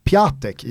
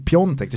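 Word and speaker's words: Piątek: Det mest eh, aktualiserade namnet i, i den här diskussionen är ju eh, Milans anfallare Kristoff Piontek som bokstavligt för en svensk då eh, ser ut att uttalas Piatek i och med att han mm. stavar P-I-A-T-E-K. Mm. mm. Men då är det då, det uttalas ju Piontek, det smyger in sig ett O och ett N där också.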